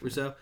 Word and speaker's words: Rousseau: I believe that's how you pronounce his name. – Yeah. – It's not. I apologize. – It is. – Rousseau? – Yep. – Rousseau. Look, I got you.